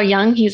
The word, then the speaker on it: Jung: And this concept of the shadow was created or founded or whatever you want to say is by Carl Jung.